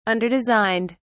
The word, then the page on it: underdesigned: British English